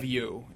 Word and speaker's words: Hivju: Hivju is how you pronounce his name.